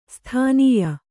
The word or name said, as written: ಸ್ತನ್ಯ